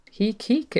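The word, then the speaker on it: Heke: So Heek Heek, it rhymes with Reek.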